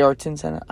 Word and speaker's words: Ayrton Senna: Arden Senna had Arton Senna, eh, Ayrton Senna. I'm so bad at pronouncing names today.